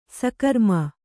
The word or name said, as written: ಸಕರ್ಮ